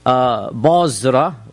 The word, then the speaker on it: Basra: Any reference of Basra, which is how it's pronounced, in the Sira literature is to a small town south of Damascus.